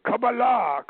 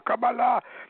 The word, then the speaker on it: Qabalah: He says, "You don't even pronounce the word right. It is Qabalah, Qabalah."